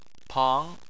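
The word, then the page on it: pang: tone 2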